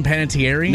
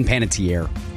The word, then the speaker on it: Panettiere: Am I pronouncing her name right? Hayden Panettiere? – No, it's Hayden Panettiere. – Tiere. Dang it.